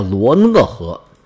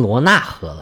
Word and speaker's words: 罗讷河: 这条河呢，在百度百科上写的是罗尼河，又有一个名叫罗讷河。言字旁一个内，木讷的讷，也有人读白字儿，就直接读成罗纳河了。